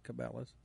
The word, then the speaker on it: Cabela's: We went over to Famous Dave's there by Capillas. Some people pronounce it Cabela's.